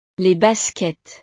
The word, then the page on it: les baskets: frances